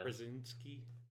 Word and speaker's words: Krasinski: From Quiet Place, John Kaczynski or whatever. Kaczynski. I can never say his name. Kaczynski, yeah. – Krasinski.